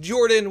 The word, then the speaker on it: Jorphdan: My name is Jordan with a silent PH in the middle, and I am joined always by my wonderful co-host, Sir Lucian, over there at Sir Lucian Gaming.